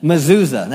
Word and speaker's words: mezuzah: Mezuzah.